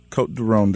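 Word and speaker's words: Côtes du Rhône: And you started, I read somewhere on your website, you started drinking Cote de Rhone.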